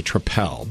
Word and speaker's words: Tripel: See, I would call it a triple, but apparently it's a tripel because I want to make sure I pronounce that right.